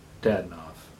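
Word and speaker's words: Dadonov: Then again, so did Dodonov. Dadnov. I can't even get the pronunciation right on the first try in his farewell address.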